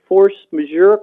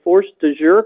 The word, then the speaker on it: force majeure: And so I know when we were talking about the force majeure clause, I called it the force de jure clause several times last meeting.